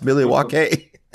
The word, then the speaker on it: Milwaukee: Like, I thought he was going to have some conversation with you about the history of Milwaukee, you know, which is, it's pronounced Milwaukee.